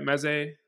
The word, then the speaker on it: Mezze: So, and then quickly, Meze Spice, am I saying that right? Meze? – Yeah. – Spices or? – Meze Spices, yeah.